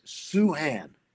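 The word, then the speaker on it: Sochan: You know, so Jeremy told me today it's Suhan.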